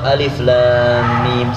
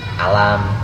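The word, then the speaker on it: alif lam mim (الم): Tapi kalau alif lam mim satu-satu, alif lam mim, bukan dibaca langsung. Alam atau bacaan yang lainnya, tapi baca satu-satu.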